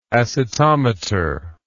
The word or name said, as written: acetometer